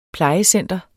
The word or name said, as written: plejecenter